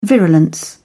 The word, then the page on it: virulence: British English